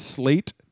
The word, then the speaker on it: Slate: My name is Slate Tatum. – How do you spell that? – It's S-L-A-T-E, and then...